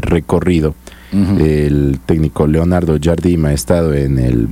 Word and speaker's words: Jardim: que es dirigido por Leonardo Jardim.